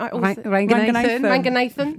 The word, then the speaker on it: Ranganathan: She's a comedian writer, has been on Live at the Apollo, currently on tour, supporting Ramesh Ranganathan. Have I said that right? – Ranganathan.